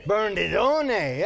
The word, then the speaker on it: Bernardone: Giovanni di Baradone. Do you say the Baradone or the Baradone? Bernardone, yeah, that's Francis of Assisi.